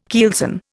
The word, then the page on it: keelson: Indian